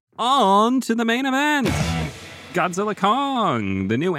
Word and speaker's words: Godzilla x Kong: The X in the title is silent for some reason. It's not Godzilla X Kong or Godzilla times Kong. It's just Godzilla Kong, the new empire.